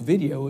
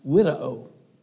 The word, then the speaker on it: video: The word regard here in this verse in the Latin language is a word that is spelled video, but it's not pronounced like that. In your Latin language, all your W's are, or all your V's are pronounced with a W, and so it's not pronounced video, it's pronounced widow widow.